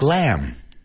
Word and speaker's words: lamb: That B is silent, like lamb.